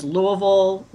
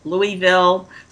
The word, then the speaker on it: Louisville: She created a PR firm in 1984 and has been serving clients in Louisville. Do I say that right? – Yeah, there's several pronunciations, Louisville, Louisville. So we accept anything.